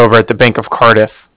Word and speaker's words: Cardiff: That's N-I-K-O. And then the Bank of Cardiff is spelled C-A-R-D-I-F-F as in Frank Frank. So it's like car and then different.